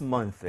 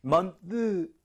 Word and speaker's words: month: 자, th는 사실 메롱이란, 메롱 사운드다. 저는 그렇게 얘기는 하지만 혀가 너무 나오지는 않아요. 많은 분들이 실수하는 게, 먼드, 이렇게 하시는 분들이 있는데, 윗니, 아랫니 사이 혀가, 으, 나오는 게 중요한 게 아니라, 살짝만 끼고 바람이 나오는 게 중요합니다.